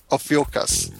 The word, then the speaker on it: Ophiuchus: Ophiuchus.